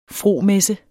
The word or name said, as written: fromessens